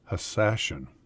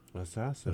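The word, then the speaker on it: Hashshashin: And these young killers came to be known as hashish eaters, which in Arabic is hassashin, or as the crusaders pronounced it, assassin. – Assassin.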